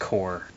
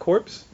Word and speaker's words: Corps: It's pronounced core. – Is it not corpse? – No, it's core. Core. As what? In Marine Corps, not Marine Corpse.